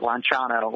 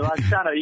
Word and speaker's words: Lanciano: Lanciano? Is that how you pronounce it? – Yes, yes. So the first one was in the 8th century in Lanciano, Italy, or Italians pronounce it Lanciano when Lanciano. – Exactly, Lanciano. You got to have your fingers in the air when you say Lanciano.